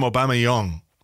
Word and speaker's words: Aubameyang: Apparently, we're supposed to call him Aubameyang. That's how you pronounce it.